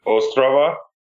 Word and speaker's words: Ostrava: So we think the proper way to pronounce it is Ostrava. – Oh, okay. – It is I have been saying Ostrava because that's just, I'm American.